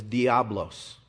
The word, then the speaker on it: Diablo: In Greek, and I believe Spanish, the word for devil is diablos.